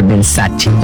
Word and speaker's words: Versace: Por ejemplo, Versace, cuando la marca Versace es italiana, entonces tengo que pronunciar Versace.